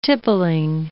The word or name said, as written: tippling